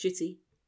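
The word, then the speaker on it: Ncuti: No, it's Shuti Gatwa. He's pronounced Shuti.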